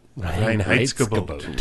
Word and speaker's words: Reinheitsgebot: But, Reinheitsgebot. – Reinheitsgebot. – Reinheitsgebot. Very good. Sounded out three syllables.